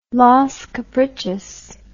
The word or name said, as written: Los Caprichos